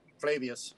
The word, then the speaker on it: Flavius: Flavius.